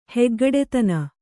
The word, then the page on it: ಹೆಗ್ಗಡೆತನ: Kannada